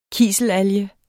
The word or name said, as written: kiselalge